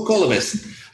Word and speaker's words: Kalibas: Kalibas? – Kalibas, yeah. – Oh, Kalibas. I had the wrong emphasis on the wrong syllable.